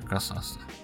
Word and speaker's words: Касас: Да, ну, как бы, это надо быть очень большим поклонником Марио Касаса. – Касас. Марио Касас. Надо так произносить, знаешь, как будто ты уже танцуешь.